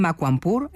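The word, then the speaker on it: Makwanpur: La sede está en Makwampur, ¿he pronunciado correctamente? – Correcto.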